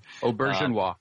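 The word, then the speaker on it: Auberjonois: Excellent episode last week with Star Trek Deep Space Nine's Rene Arjbenoit. I'm butchering his last name. – Aubergenois. – Thank you.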